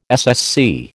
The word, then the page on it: SSC: American English